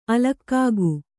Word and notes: ಅಲೌಕಿಕ: alaukika

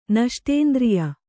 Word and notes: ನಷ್ಟೇಂದ್ರಿಯ: Kannada

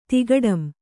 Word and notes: ತಿಗಟಂ: tigaṭam

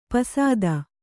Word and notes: ಪಸದ: kannada

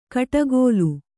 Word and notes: ಕಡಗೋಲು: kannada; kaḍagōlu

